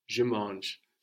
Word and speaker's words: mange: We write it MANGE to make sure that we pronounce that G. No? But to the ear, it's manger without the e ending.